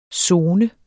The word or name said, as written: sone